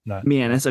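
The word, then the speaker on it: Meanas: Adjacent how do you say it? – Mianus.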